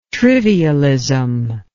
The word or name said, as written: trivialism